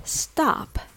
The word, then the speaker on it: stop: Now the word stop, it's pronounced with the vowel ah. Open your mouth a little wider than O.